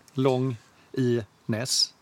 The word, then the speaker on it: Longines: Men det, det heter Longines. Long i Ness.